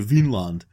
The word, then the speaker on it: Vinland: And there's also the argument that the name is Vinland, as opposed to Vinland, but with a short I, in which case it would mean something more like meadowland.